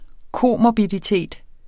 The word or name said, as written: komorbiditet